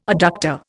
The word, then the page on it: adductor: Indian